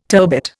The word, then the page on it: turbit: Indian